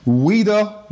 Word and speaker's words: Ouida: Ouida Walls. The Ouida is O-U-I-D-A. I will always have to clarify that it's French, so the O is silent.